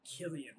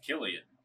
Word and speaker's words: Cillian: The actor who plays him, I mispronounced his name. I said it was Cillian Murphy. It is actually Killian Murphy. – Killian.